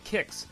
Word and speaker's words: Qix: Hitting digitally, we will start with this week's Arcade Archives release for the 1981 Taito Arcade Classic, the puzzle action title Kix, spelled Q-I-X, but pronounced Kix, as GSK handily notated.